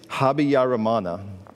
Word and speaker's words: Habyarimana: It is Habyarimana.